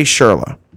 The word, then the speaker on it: Schürrle: Let's get to Andre Schürrle. Brian, did I say that right? – You sure did.